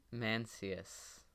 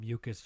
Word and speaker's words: Mencius: Mencius. – I said Mucus just to bother my friend, but. – I don't know if I actually pronounced that right. – But it is.